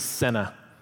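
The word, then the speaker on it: senna (סנה): Bush in Hebrew is senna. That's how you say it.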